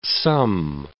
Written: some

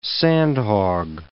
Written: sandhog

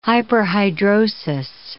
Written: hyperhidrosis

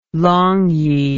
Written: longyi

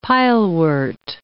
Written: pilewort

